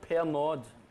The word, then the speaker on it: Pernod: It's aniseed, and it would be called pernod here, but it's silent D, pernil or Yes.